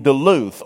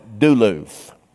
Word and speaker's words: Duluth: And they had a store in Cumming, and they had a store in Duluth, or as we country boys used to pronounce it, Duluth.